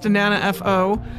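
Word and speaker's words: 7FO: And it's written seven, like the number seven, F-O, but pronounced Nana F-O. Nana meaning seven in Japanese.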